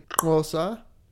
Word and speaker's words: Xhosa: I would point out though, that this is really fucking weird that I would know this, but Sean mispronounces the name of the language that he's supposedly speaking. It's actually Xhosa is how you're actually pronouncing the language that he's speaking. It's spelled yes. in English Xhosa, Xhosa, but he says Xhosa or something.